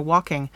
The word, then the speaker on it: waulking: So, one of the places that singing and weaving come together is at a waulking.